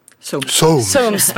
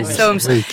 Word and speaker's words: Soames: On prononce comment ? – Soames, pardon, c'est un Britannique en effet. Soames.